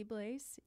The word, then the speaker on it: Blaze: I am at Lindsay Blaze. You spell Sick. – My name with an A, and my last name is spelt like Blaze like a fire.